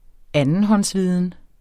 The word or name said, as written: andenhåndsviden